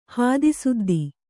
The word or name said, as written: ಹಾದಿಸುದ್ದಿ